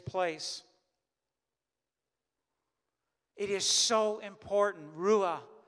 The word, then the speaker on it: Ruah: In the Hebrew, the word is Ruah. So if I would spell it R-U-A-H, Ruah, breath. We've been talking about giving thanks this morning, that there would be a giving thanks for Ruah, for the breath that we have.